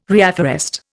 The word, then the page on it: reafforest: Indian